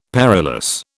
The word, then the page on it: perilous: British English